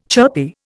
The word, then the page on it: chirpy: Indian